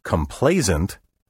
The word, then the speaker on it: complacent: Complacent, with an S sound in the final syllable, has a negative connotation.